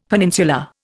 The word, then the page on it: peninsula: Indian